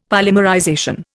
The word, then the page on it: polymerization: Indian